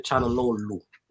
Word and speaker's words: Çalhanoğlu: The G is silent, he said. – Çalhanoğlu.